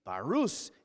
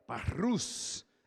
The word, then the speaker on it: barous: Everybody say it with me. Barous. If you can roll your R's, do that, right? Barous. That's really how it's pronounced.